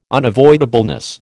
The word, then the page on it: unavoidableness: American English